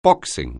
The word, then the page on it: boxing: anglais